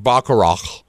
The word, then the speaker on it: Baccarat: Okay, I think I'm saying it wrong. Baccarat. Being corrected in the live chat. They love me. – Trying to educate you. – If you take Baccarat out of that number, instead of being down 9%, we're actually up 4.7%.